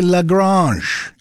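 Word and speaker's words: La Grange: You know, I got thinking about Home on the Range and LaGrange, and I wondered, am I even pronouncing that right? Well, I looked it up. It's actually LaGrange. It's French. It literally means the barn in French, LaGrange. It's a town in Texas, LaGrange. Got its name from French origins. Although today, Texans pronounce it simply LaGrange.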